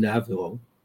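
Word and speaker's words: Naviaux: It's led by a fellow named Robert, I think he pronounced his last name, Navo.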